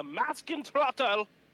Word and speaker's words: Maskentrottel: Call them a Maskentrottel or mask idiot. That is the correct pronunciation.